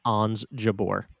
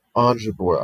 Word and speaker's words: Ons Jabeur: I'm interested to see what Jons Jabeur does. Did I just spell that? Did I say that? Oh my God, right? – Ons Jabeur. – Ons Jabeur. I am so sorry.